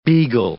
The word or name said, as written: beagle